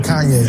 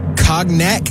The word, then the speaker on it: Cognac: Sounds like she said Kanye. – It did sound like she said Kanye. I believe it's pronounced Cognac.